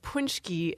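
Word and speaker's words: paczki: And then we also have punschki, which given the spelling, P-A-C-K-Z-I, you wouldn't necessarily know to pronounce that punschki unless you'd heard it or someone told you how to say it.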